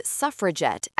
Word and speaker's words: suffragette: The Daily Mail coined suffragette as an insult. The WSPU proudly adopted it with a hard G.